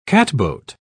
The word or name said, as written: catboat